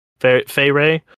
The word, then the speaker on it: Feyre: I've said her name so much, it's just in there. Yeah, it's pronounced Feyre.